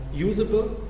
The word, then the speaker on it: uzbl: Es gibt einerseits das Usable, also UZBL wird ausgesprochen als Usable.